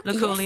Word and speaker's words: Luculia: Is it Luculia tree? Luculia tree? – Luculia yes.